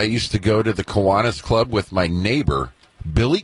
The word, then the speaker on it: Kiwanis: Is it Kiwanis or Kiwani? – The Kiwanis. – Right? Kiwanis. – Yeah. – Kiwanis. All right. I had it right the first time.